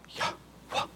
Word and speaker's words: Yahweh: They weren't allowed to pronounce the word, the name Yahweh, but they believe that, the Hebrew scholars believe it was pronounced Yahweh. It was about breath. Kind of like a gentle blowing wind. Yahweh. Yah. And so Elijah's name means Yahweh is God.